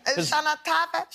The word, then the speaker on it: Shana Tova: Did I say it right, Shana? – Shana Tova. – Tova? – Shana Tava? Shana Tava, honey.